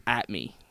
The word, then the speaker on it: @ MEH: This is At Me. – Okay, it's At Me. But it's spelled M-E-H. – But it's spelled M-E-H, and it's an at symbol.